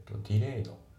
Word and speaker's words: delayed: で、あと発音なんだけど、 delayed、 delayed の D が抜けますね。